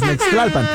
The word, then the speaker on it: Nextlalpan: Así es que por allá te vamos a esperar con todo el flow, con todo el sonido de la salsa en Ixtlalpan. Oye, casi rimó, casi a excepción del Nextlalpan, porque no se pronuncia así, es Nextlalpan.